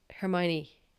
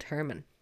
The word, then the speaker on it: Hermione: So like when I started reading the books, like I read them in my head, and the movies obviously weren't out, so I'd never heard her name being pronounced, so I assumed she was called Herman.